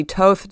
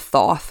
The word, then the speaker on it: Thoth: The Thoth deck or the Toth deck, I don't really know how you say his name, you know, there's multiple pronunciations, but I'm going to go with Thoth because that's what I've heard a lot.